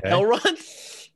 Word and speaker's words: Elrond: You see Eldron. – Okay. Elrond? – Did I say it wrong? Eldron. No, still not. There's no D at the beginning.